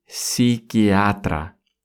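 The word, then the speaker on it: psiquiatra: И, соответственно, психиатр будет «психиатра». «Психиатра». Пишется с первой буквы «П», но мы ее не произносим.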